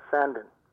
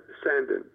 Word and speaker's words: Sandin: So it's S-A-N-D-I-N. – And Sandin, but it's Sandin. It's like... – Yeah, that's the way they enunciate it. – Yeah, that's the way they pronounce it.